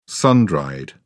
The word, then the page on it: sun dried: British English